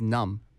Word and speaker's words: numb: what it means is numb. 그쵸, 맞아요. – N-U-M-B. – 맞아요. – 네. – N-U-M-B. Numb. 하지만 우리 그 B 발음은 안 나와요.